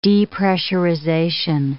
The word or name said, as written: depressurization